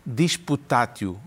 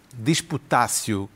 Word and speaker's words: disputatio: disputatio, ou disputácio, como se ensinava antigamente, antes de entrar em vigor a chamada pronúncia restaurada.